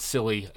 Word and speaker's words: Scilly: S-C-I-L-L-Y. Not I'm being a silly wizard, but it's silly. And that's how they say it.